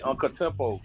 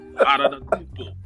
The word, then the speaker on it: Antetokounmpo: I feel very happy for Giannis Antetokounmpo. It took me about a week to learn how to say his name when I first learned it. – I say Uncle Tempo. – Antetokounmpo.